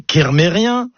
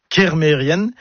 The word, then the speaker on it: Kermérienne: Et puis un autre mot, par contre, qui lui n'est jamais bien prononcé, c'est Mérienne. Mérienne, comme ça ne vous parle pas. Mais si je dis Mérien, bah oui, vous allez reconnaître le nom de famille, Kermérien, le nom de lieu. Eh ben non, tout ça c'est faux, c'est Mérienne, Kermérienne.